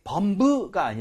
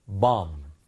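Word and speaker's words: bomb: 발음할 때 범브가 아니라 밤. – B는 뒤에 있는 B는 묵음인 거예요, 여러분들.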